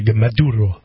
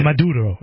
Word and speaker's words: Maduro: I'm surprised you don't see your usual spiel, which is to pronounce it overly Latino and be Maduro. – I don't know if you have to roll your R's. I don't think Maduro. It's just the Uro.